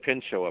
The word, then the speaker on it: Pinchot: Did I say that right, Pinchot, or is it Pinchot? – We say it Pinchot up here.